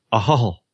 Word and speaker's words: Ahoghill: I'm in Ballymena in Northern Ireland, in a little town spelt A-H-O-G-H-I-L-L, and it's pronounced Ahol.